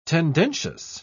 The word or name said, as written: tendentious